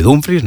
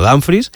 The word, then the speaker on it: Dumfries: Está ya Dumfries en Madrid, que él mismo ha dicho que se dice así, se dice Dumfries, no Danfries, y el club trabaja en cerrar la llegada de Diomandé y en iniciar las conversaciones con el Manchester City por Rodri.